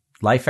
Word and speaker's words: Leif: So it just happens to be coincidence that it's pronounced Leif? – Columbus Day. Leif Erikson? I pronounce it Leif, but I don't mind being corrected on that.